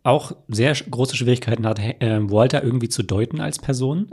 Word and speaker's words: Walter: Hank wird zu Hause zu Marie sagen, Hank, Marie und Walter, die Namen werden englisch ausgesprochen. Es ist Marie und Walter.